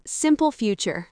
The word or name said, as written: simple future